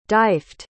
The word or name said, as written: Dyfed